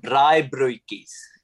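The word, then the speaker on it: braaibroodjies: In South Africa, we call them braaibroekies. Can you say that? – I mean, my pronunciation's always lacking. Braaibroekies? Braaibroekies? – Yeah, that's pretty good.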